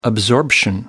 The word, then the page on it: absorption: American English